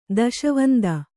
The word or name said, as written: ದೆಸೆವಂತ